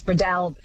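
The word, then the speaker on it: Breadalbane: David in Tamworth says, I always understood it to be Bradalban with the emphasis on the second syllable, the third almost not pronounced, which is such an Aussie way of doing it. Bradalban, do you think, does that sound good? – Close, more A on the A, yes, Bradalban.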